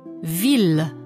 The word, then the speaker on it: ville: ILL wird wie J in Ja ausgesprochen. – Fille. Billet. Famille. – Ausnahme. – Ville, Mille.